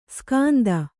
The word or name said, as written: ಸ್ಕಾಂದ